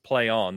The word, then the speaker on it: Plaion: It's P L A I O N. – Uh, Thanks, trademark it is, lawyers. – Yeah, it is pronounced play on, they confirmed.